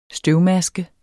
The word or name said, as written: støvmaske